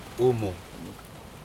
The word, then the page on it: umu: Aneityum